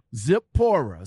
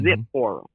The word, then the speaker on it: Zipporah: Yes, a lot of people say Zipporah. Yeah, but see, a lot of people say Zipporah, Z-A instead of Z-I. It's Zipporah. But in the Old Testament, it's actually Sephora, and it's spelled S-E-P-H-O-R-A.